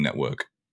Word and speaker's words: NTWRK: I think it's Network, right? – N-T-W-R-K. – The twerking network. – It is just Network. They've just taken all the vowels out, right? – Yeah.